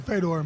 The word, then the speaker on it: Fedor: Matt Mitrione is here, and he's going to be fighting Fedor, or whatever. It's. How do you pronounce it, Matt? – Well, I pronounce it Fedor, but technically I believe in Russia it's Fedor.